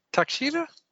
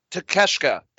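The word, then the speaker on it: Takeshita: No, that's not how it's not. Takashita? It's not like Takashita. Felt very bad. I'm sorry, I don't watch a lot of W. – Takeshka. That's how you say it.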